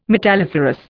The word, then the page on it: metalliferous: Indian